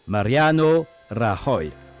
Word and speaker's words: Mariano Rajoy: moi je vais le prononcer à l'espagnol, bien sûr, je me souviens un petit peu de mes cours, c'est pas évident, mais c'est Mariano Rajoy.